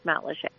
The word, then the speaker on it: Matlacha: It's spelled Matlacha, but It's pronounced Matlacheay.